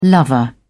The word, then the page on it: lover: British English